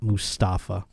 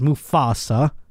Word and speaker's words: Mufasa: Of course I know it's Musafa and not Mustafa or Mufasa. I'm saying it wrong again, aren't I, Kirsten? – Just slightly. You're good. – Thank you. It's Mufasa.